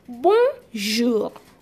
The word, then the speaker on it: bonjour: That is why we have the word to be bonjour. Bonjour. Now you don't hear people saying bonjour. Never. The letter R is not pronounced. You have it as what? Bonjour.